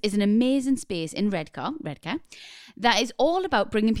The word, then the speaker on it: Redcar: It's in Redcar, or Redcar, as the locals say, in the northeast.